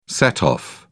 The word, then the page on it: set off: American English